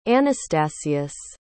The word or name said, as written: Anastasius